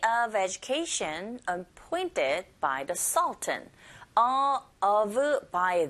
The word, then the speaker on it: Sultan: 네, 우리는 술탄이라고 읽지만 발음은 Sultan 이렇게 하시면 돼요.